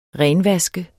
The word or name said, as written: renvaske